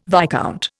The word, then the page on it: viscount: Indian